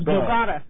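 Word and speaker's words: Bogotá: Yeah, and that's how I pronounce it because it, by looking at it, it doesn't look like Bogota, you know what I mean, by the way. – No, it's Bogota.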